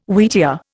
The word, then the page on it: wheatear: Indian